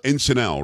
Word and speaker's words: Encinal: And this is pronounced Encinal, right? – That's correct.